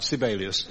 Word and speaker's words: Sibelius: To prevent us getting letters, I'll say it right, Sibelius.